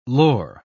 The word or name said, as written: lore